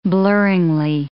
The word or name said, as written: blurringly